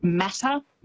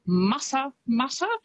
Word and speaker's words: Matamata: Quite often, because of how it's spelt, people will pronounce it Matamata, but it's Matamata, which is, I think, a lovely way to say it.